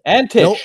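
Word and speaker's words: Antić: That was by Ivan Antich, or Antic, I don't know how to say it. – Antich, that's correct.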